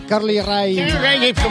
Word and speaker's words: Carly Rae Jepsen: Carly Rae Jepsen. – Sí, es que no sabía cómo se dice. Carly Rae Jepsen.